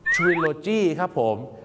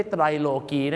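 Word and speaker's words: trilogy: ทริโลจีครับผมไม่ใช่ไตรโลจีครับและไม่ใช่ไตรโลกีแน่ๆนะครับ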